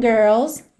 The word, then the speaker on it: girls: E naquela rápida revisão de como pronunciar a palavra girls, especialmente com sotaque mais americano, que tem aquele R enrolado da porta com a perna esquerda.